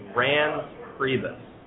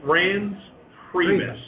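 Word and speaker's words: Reince Priebus: So the most common that I've heard is Rans Priebus. – Yeah. – Rans Priebus.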